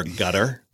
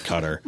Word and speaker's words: Qatar: So, they pronounce it over there, gutter. – Got it. – Which sounds like a gutter on your house. I call it cutter.